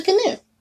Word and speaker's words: gnu: I don't know what the actual pronunciation is, but It's gnu because, okay, have you ever seen the, remember the Great Space Coaster? – yes. – And the character was named Gary Gnu, and he pronounced it for you. That's how I learned how to say it.